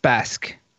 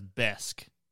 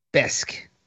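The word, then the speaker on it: bäsk: Malort falls in the category of Brännvin, known as Basque. – I saw another that said it's pronounced as Besk. – Besk. – So just say it like as in, instead of an A, there's an E.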